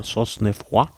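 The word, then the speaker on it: Cosnefroy: Wie spricht man es aus? – Sosnefroid.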